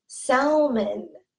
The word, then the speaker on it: salmon: The L is totally silent. We do not say salmon.